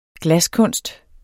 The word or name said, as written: glaskunst